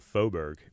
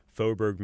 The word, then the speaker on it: Faubourg: We finally got to a name called Faubourg, which practically no one pronounces correctly the first time. But if you're in New Orleans, Faubourg Marigny, Faubourg Treme, that is French for neighborhood.